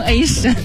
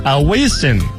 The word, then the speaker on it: Avicii: 我罚你念上榜理由，就是让你念他的名字，你居然每个都给我念成 A 神。 – Avicii。